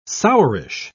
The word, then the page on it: sourish: British English